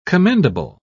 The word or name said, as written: commendable